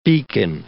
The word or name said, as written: beacon